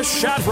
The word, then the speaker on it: SHATG: And now it's time for The Shat Report. Stuff happening around the globe. Uh, uh, the the G is silent.